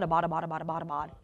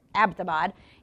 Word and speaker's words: Abbottabad: can we pronounce it correctly? It's not Abbottabad. It's Abbottabad.